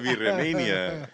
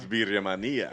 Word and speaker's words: Birria Mania: It's not Birria Mania. It's Birria Mania. – Birria Mania. – Yeah, I like that. – Love it. – That's the official way to pronounce it from now on.